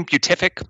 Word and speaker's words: beatific: Beatific. I'm not sure how to pronounce that word. – Beatific. – There you go.